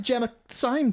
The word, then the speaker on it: Gemma Syme: Rhymes with crime, sort of. – Gemma Crimes. Oh, that would be like your MC name. – Gemma Crimes. Gemma Crimes. – So you're talking to Gemma Syme today? – I am.